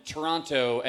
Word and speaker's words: Toronto: I've been corrected several times every time I have said Toronto and pronounced the second T. Yeah. – What's up with that? – So what? Why, though? – Better? – Better no T?